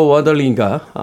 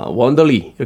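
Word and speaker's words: Wanderley: 아스트로드 질베르토와 월터 원더리가 아, 원더리 이렇게 발음해죠 원더리 네.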